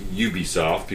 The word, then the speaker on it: Ubisoft: So Ubisoft, uh, and it is pronounced Ubisoft because ubiquitous software. It's not Ubisoft.